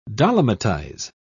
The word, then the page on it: dolomitize: British English